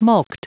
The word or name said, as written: mulct